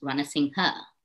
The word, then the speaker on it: Ranasinghe: First of all, my name is pronounced Ranasinghe.